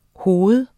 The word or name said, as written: hoved